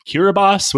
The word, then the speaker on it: Kiribati: Now, nowadays, they make up the part of them make up the nation of Kiribati, which I promise you, if you look at how this island nation is spelled, you will not believe it's pronounced that way. – I was going for Kiribati, so.